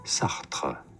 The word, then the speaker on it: Sartre: This French name, the name, of course, of a famous French philosopher from history. So in France, it is said as Sartre, Sartre, as in Jean-Paul Sartre.